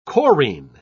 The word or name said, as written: chorine